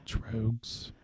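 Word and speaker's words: Tröegs: I said Trogues. – Trogues. It's because there's an umlaut over the O. – Yeah, so it's T-R-O with an umlaut, E-G-S.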